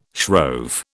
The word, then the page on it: shrove: British English